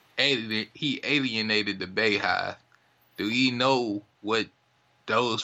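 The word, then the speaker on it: Beyhive: It's the Beehive. – I see folks spell it B-E-Y. – So yeah, they say B-E-Y, but it's the Beehive.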